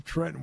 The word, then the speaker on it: Trenton: And, of course, the running joke is from Dick, anytime we bring up Trenton, we have to pronounce it the way they do there. It's Trenton.